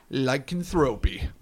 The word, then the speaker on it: Lycanthropy: Lycanthropy.